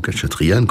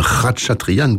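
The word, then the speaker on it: Khatchatourian: Finale de la sonate pour violon et piano de César Franck par Sergueï Khatchatryan, ou Khatchatryan comme vous voudrez, ou même Khatchatryan comme il faudrait dire, et sa sœur Luzine. Eh bien, ce jeune virtuose a gravé le concerto de Khatchatryan, qui faudrait aussi prononcer donc Khatchatryan, mais bon voilà, on a l'habitude de dire Khatchatryan et ou Khatchatryan,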